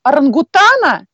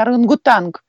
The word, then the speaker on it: орангутан: Или Например, в прошлом году у нас был доклад от одной из девочек про орангутана, и она обратила наше внимание, что правильно говорить орангутан, а не орангутанг.